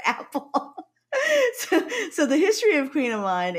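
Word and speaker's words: kouign-amann: Why is it pronounced Queen Amon? And it's spelled K-O-U-I-G-N, but they decide to pronounce it Queen. Why don't they just spell it Q-U-E-E-N?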